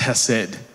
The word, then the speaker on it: hesed: The word in Micah 6 verse 8 in the Hebrew is actually pronounced hesed, which is known as loving kindness.